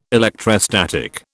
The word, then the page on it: electrostatic: British English